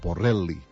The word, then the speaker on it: Borrelli: Paco Torres, avui en Jean-Claude Borrelli, o Borrelli, com li diuen, així sense pronunciar l'el.